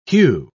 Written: hue

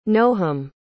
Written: noham

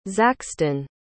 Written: zaxton

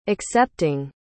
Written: accepting

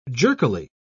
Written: jerkily

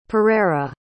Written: Pereira

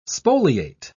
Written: spoliate

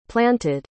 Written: planted

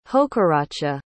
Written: hokaratcha

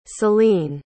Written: salene

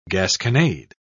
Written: gasconade

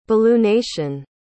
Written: balloonation

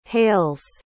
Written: hales

